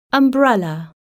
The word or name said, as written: umbrella